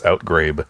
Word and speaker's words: outgrabe: I'll meme see where the borrow goes, and the moan rats outer gabe. – I think it's outgrabe.